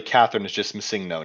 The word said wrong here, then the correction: Masego: now. Oh, Masego, sorry, I'm mispronouncing that.